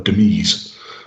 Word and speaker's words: demise: He, uh, he, he went what I'm presuming is the original French version, called demise.